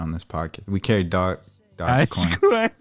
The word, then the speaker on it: Dogecoin: No, dude's like, no, it's doggy coins.